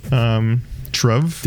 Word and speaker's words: Trve: I think it's Truve, T-R-V-E. Truve.